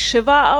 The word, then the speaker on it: shiva: Also wenn die, äh, ich glaube, das ist bei in den Moore-Büchern, wo die, äh, ich glaube, es spricht sich Shiva aus, Shiva sitzen, ist eine mhm.